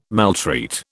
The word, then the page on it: maltreat: British English